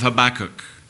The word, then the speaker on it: Habakkuk: You can begin to make your way to the Old Testament, to the book of Habakkuk. Different ones pronounce it differently, but that's how I've always pronounced it.